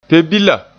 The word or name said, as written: pe-bila